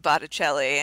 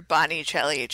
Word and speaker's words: Botticelli: As she's taking pictures of Ty, she mispronounces the name of Botticelli and calls her a Bonicelli chick, but she knows who Botticelli is, which I think is great.